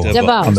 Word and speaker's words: DeVaux: Cherie DeVoe. Is that how you say it? DeVoe? D-E-V-O-U-X. – Elvis, that sounds boozy. How do you say it? – D-E-V-O-U-X. – I'm not going to answer you. How do you spell it? – D-E-V-A-U-X. Cherie DeVoe. – I think DeVoe. I heard DeVoe. – DeVoe. So congratulations. – As in Belle Biv DeVoe.